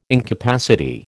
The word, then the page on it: incapacity: American English